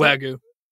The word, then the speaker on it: Wagyu: Wait, how do you pronounce Wagyu.